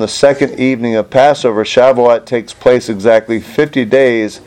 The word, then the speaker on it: Shavuot: The Jewish festival of Shavuot, am I pronouncing that right? – I believe it's Shavuot.